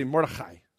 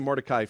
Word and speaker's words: Mordechai: Mordechai. Everybody say Mordechai. Good, I'm going to say Mordecai from now on because that's hard on me. But we all know how to say it.